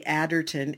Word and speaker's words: Aderton: My name is spelled Sally Adderton, A-D-E-R-T-O-N.